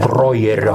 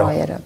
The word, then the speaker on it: Brojerio: Ir man labai patiko pokalbėje Nyčias ir Brojerio ar Brauerio, nes labai skirtingai dabar ištarė. – Brojerio. – Brojerio, tai va, Brojerio.